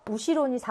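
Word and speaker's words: 우시로 (後ろ): 얘는 아또라고 읽어주고 얘는 우시로라고 읽어줘야 돼요. 아또는 시간적인 뒤, 우시로는 공간적인 뒤.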